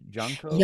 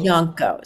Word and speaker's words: Janko: There's the how would you pronounce the name? Janko? – Yanko. So his name, Janko. – Yanko. Yeah. John, when John was born in Mainz, Germany, and at that time his nickname was Yanko.